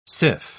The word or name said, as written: syph